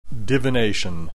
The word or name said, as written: divination